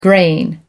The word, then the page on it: graying: American English